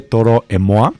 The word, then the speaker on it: Toro y Moi: Se escribe Toro y Moi, pero obviamente no es la banda de Toro y Moisés. Es francés de Toro et Moi. Es Toro et Moi en francés.